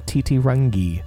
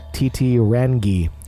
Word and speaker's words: Titirangi: I used an enunciation point that somebody gave for me and didn't just try to pronounce it by myself. – Yeah? – Titirangi.